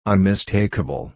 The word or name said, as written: unmistakable